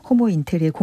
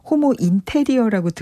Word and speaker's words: 호모 인테르: 호모 인테르의 공동대표십니다. 이거 제가 발음 잘해야 될 것 같아요. 혹시 호모 인테리어라고 들으시면 안 되는데. – 네, 맞아요. – 호모 인테르.